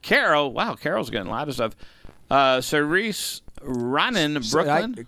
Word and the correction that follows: Saoirse: Saoirse Ronan is how you pronounce her name.